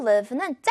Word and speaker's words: live: 발음하실 때요, 주의할 게 요 live는 짧게, 요 I 있죠, 여러분.